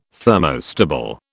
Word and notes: thermostable: British English

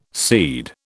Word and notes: cede: British English

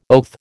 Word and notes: oath: American English

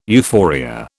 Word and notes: euphoria: British English

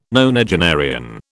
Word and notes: nonagenarian: British English

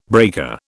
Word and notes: breaker: British English

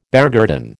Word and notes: beargarden: American English

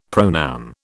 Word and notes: pronoun: British English

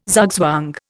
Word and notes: zugzwang: Indian

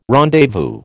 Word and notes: rendezvous: American English

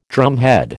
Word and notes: drumhead: American English

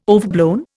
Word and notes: overblown: Indian

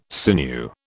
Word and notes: sinew: British English

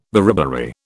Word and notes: beriberi: British English

